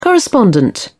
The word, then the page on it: correspondent: British English